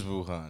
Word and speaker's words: Wuhan: Believe Wuhan. – It is Wuhan.